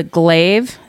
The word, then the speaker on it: glaive: It's called a glaive. It's spelled G-L-A-I-V-E.